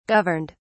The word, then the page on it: governed: British English